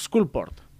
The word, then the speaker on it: Skullport: Com es pronuncia això? Scoundrels and Skulldagger? Spool... No. Seguidor... Scoundrels of Skullport.